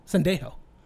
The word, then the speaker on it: Sendejo: Sendejo.